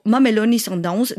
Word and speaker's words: Mamelodi Sundowns: Mais par exemple, il y a une autre affiche rare, les River Plate d'Argentine face aux Mamelodi Sundowns d'Afrique du Sud.